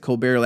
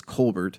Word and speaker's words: Colbert: I think I pronounced it Colbert last time when I got corrected. It's Colbert.